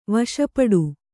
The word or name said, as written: ವಶಪಡು